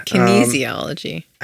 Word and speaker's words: kinesiology: And Jessi has a degree in kinesiology. – What? – Yeah. – Kinesiology. – I don't know how to say anything. – I just told you how to say it. – Kinesiology? Yes. Okay, we'll ask Jessi.